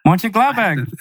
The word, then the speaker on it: Mönchengladbach: Go on, Mönchengladbach. – Yeah. Why is this? You said it right. – I did. – Mönchengladbach.